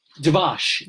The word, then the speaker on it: dvash: How do you say it? – Dvash. D, Dalet for Dvash.